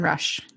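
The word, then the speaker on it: Rusch: But he writes science fiction and he's married to a woman who is absolutely beautiful and she's an expert, Catherine Rush. Am I pronouncing that correctly? – Yeah, Christine Catherine Rush.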